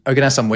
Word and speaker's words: oganesson: Oganesson, which I, to be fair, that's the first time I've heard anyone pronounce it with any confidence, so...